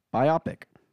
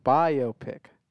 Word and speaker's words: biopic: Biopic. Are you looking it up? – Mm-hmm. The word is pronounced biopic. Not biopic. – How are you reading that? – B-I all caps and then O-P-I-C lowercase.